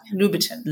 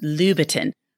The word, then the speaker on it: Louboutin: So I'm very bad at these, but it's actually Louboutin. Louboutin, like that. Louboutin. – Louboutin. I recognize the Louboutin, and now I know how to say that, but I did not know the others.